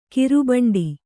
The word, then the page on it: ಗೋರಿಬೇಂಟೆ: gōri bēṇṭe